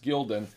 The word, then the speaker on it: Gaudens: Gildan is spelled G-A-U-D-E-N-S, but it's pronounced Gildan.